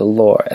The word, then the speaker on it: Lor: But before we get started, I wanted to draw attention to the opening song, the Bird Flu Sports Remix by the late Lore Scooter. And for those who don't know, that's spelled Lore, L-O-R, which is sort of a Baltimore accent for little.